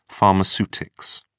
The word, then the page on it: pharmaceutics: British English